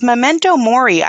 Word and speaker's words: memento mori: But this memento mori, I thought, did I pronounce that right? – Yeah, that's right.